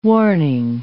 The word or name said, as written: warning